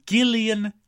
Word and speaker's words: Gillian: No, it's not. Gillian. Mm-mm. She pronounces her name Gillian Jacob.